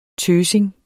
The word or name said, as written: tøsing